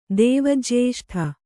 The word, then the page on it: ದೇವಜ್ಯೇಷ್ಠ: kannada